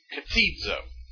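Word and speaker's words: Katizo (κτίζω): Katizo. K-T-I-Z long O, katizo.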